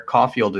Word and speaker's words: Caulfield: Caulfield is spelled C A U L F I E L D.